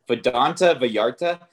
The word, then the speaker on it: Vidanta Vallarta: Vidanta Vallarta.